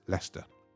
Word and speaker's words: Leicester: And Leicestershire is Leicester.